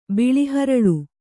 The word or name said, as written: ಬಿಳಿಹರಳು